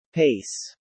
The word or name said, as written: pace